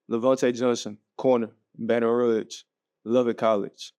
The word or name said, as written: LaVonte Johnson